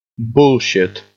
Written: bullshit

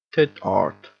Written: tit art

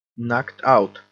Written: knocked out

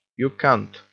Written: you cunt

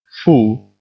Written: fool

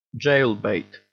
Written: jailbait

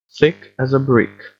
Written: thick as a brick

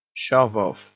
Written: shove off

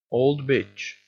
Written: old bitch

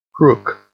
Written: crook

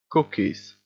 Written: cookies

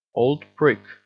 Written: old prick

